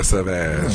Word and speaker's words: Sauvage: How unsurprising was it that his cologne, Sauvage, has a syllable in it that sounds a lot like Vag? – Yeah, Sauvage.